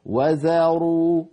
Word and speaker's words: وذروا: فلا يصح أن نقول وذروا كما نسمع من بعض إخواننا، وذروا فيفخم الواو ويفخم الذال حتى تكاد تصير ظاء، حتى تكاد تصير ظاء، لأن المخرج نفسه الذال والظاء من المخرج ذاته، إلا أن الظاء مستعلية مطبقة مفخمة والذال مستفلة مرققة مفتوحة. انتبهنا؟ وذروا وذروا هكذا ننطقها، بارك الله فيكم.